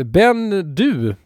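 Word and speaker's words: Ben Dubh: Och vad heter Ben Du, Dubb. Ben Dubb. Jag tror att det ska uttalas Bendu och det, var helt rätt, den är alldeles kolsvart.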